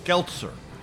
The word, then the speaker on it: Geltzer: Last name rhymes with Seltzer, Josh Geltzer, right? – That's right.